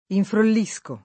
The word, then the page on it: infrollire: lingua italiana